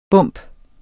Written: bump

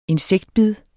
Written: insektbid

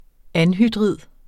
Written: anhydrid

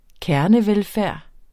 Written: kernevelfærd

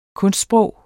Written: kunstsprog

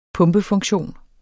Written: pumpefunktion

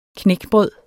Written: knækbrød

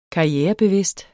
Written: karrierebevidst

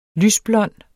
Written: lysblond

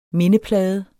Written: MINDEPLADEN

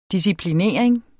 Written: disciplinering

